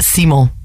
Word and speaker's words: Simon: It's like Simon, but Simon. – Simon. – Simon. Don't pronounce the N.